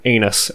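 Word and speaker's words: Enos: And Rhett Lashley, as the offensive coordinator, is a clear upgrade from Dan Anus, as we determined was the correct pronunciation last year.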